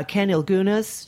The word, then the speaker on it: Ken Ilgunas: I have a, an author in named uh, Ken Ilgunas.